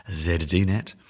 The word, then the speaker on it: ZDNet: I'm going to go for the British pronunciation, ZDNet, about tech investment falling as Brexit uh, gathers strength, maybe.